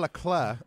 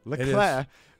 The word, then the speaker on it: Leclerc: He bet on Ferrari driver Charles Leclerc. I think is how you say it. – It is. – Leclerc.